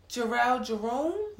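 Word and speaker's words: Jharrel Jerome: Jarrell Jerome? – Yes. – Am I pronouncing that correctly? – Yes.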